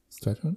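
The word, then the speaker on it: Strathairn: Oh Gott, wie wird er ausgesprochen? Strathern? Strathern? – Ja. Heißt Strat er, ja? David Strathern, ja.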